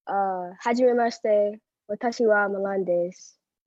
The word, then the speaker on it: Hajimemashite: Am I saying it right? Hajimemashite? – Hajimemashite. – Hajimemashite. Is that right? I'm saying two different things. I know I am. – Haji. – Haji. – Me. – Me. – Maste.